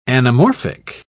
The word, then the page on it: anamorphic: British English